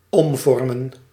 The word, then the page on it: transform: [tɹæns.ˈfɔɹm]; /trænzˈfɔːm/; /trɑːnzˈfɔːm/; /tɹɑːns.ˈfɔːɹm/; /tɹæns.ˈfɔːɹm/; /tɹɑːns.ˈfɔːm/